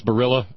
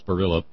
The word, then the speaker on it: Barilla: Oh, it is Barilla? Okay, thank you, Kim. Kim from New York City. It's Barilla. L's are pronounced.